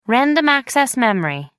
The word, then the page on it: random access memory: American English